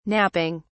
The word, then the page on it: napping: British English